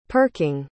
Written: perking

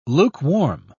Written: lukewarm

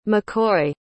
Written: maccoy

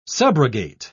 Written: subrogate

